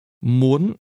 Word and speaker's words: muốn: Muốn The word for want is very similar to the word late used to say later than that, but want is pronounced with a high rising tone. Muốn. And the word for late is pronounced with a low, falling, broken tone. Muộn.